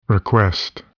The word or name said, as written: request